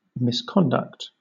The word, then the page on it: misconduct: British English